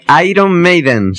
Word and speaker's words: Iron Maiden: Iron Maiden, señorita, lo estoy pronunciando bien, o sea que no me llames otra vez.